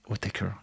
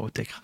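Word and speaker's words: Autechre: Parce que oui, avec ses copains de Square Pusher, O'Taker, hein, ça se prononce O'Taker et pas O'Taker,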